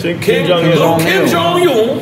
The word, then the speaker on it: Kim Jong-un: Little Kim Jong-un. He got a little Kim Jong-un. That's how you say it? – Kim Jong-un.